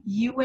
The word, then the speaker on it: Yue: Yue is still pretty.